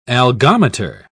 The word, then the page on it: algometer: British English